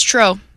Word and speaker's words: Treaux: Then we have, and I'll pronounce it wrong, Tro Cajun Barbecue. – Uh-huh. T-R-E-A-U-X. – T-R-E-A-U-X, yes. – Mm-hmm. Yep, that's Tro.